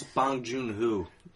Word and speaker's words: Bong Joon-ho: It's Bong Joon-ho.